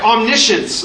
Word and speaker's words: omniscience: It's the right way, not right, not pronounced right. Omniscience.